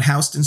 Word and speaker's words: Houston: And I give him props for saying Houston Street, even though he's only been to New York once last week. But okay. – That is the proper pronunciation of the New York Street.